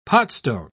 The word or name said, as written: potstone